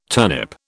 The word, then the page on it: turnip: British English